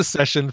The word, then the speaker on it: Seshen: The San Francisco Bay Area has spawned a band called The Session, S-E-S-H-E-N, Session. So don't spell it like you think you want to spell it. Spell it S-E-S-H-E-N.